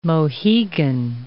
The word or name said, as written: Mohegan